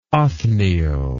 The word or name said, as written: othniel